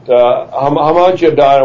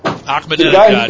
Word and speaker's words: Ahmadinejad: But I actually, I'm sure he's part of the Freemason game, but Hamadjadon, or whatever, I can't pronounce his name. – Ahmadinejad.